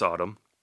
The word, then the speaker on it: Atum: It's spelled A-T-U-M. Not like Autumn, A-T-U-M, but it's pronounced Autumn.